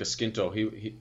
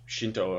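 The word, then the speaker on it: Scinto: And in that, his former manager, his name is Luca Skinto, or Shinto, or whatever way you want to pronounce it if you're an Italian. As an Irishman, I'll go with Skinto. – So, I would go with Shinto, but I mean, right.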